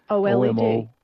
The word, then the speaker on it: OLED: OLED.